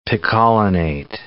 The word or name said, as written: chromium picolinate